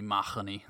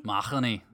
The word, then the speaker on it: Mahoney: But we were originally the O'Mahonys, which I was told from a librarian in Madison, Wisconsin, that I'm saying my name wrong. It's supposed to be Mahoney. – Mahoney? – Mahoney.